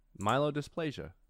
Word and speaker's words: myelodysplasia: I can't even pronounce the disease he had. Myelodysplasia. Myelodysplasia? How do you pronounce this? – Looks right to me. – No, I gotta know. Myelodysplasia pronunciation. Oh, myelodysplasia. I actually got it almost right.